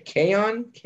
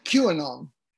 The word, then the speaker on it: QAnon: How do you say it? KON? – QAnon.